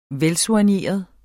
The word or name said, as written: velsoigneret